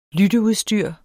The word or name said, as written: lytteudstyr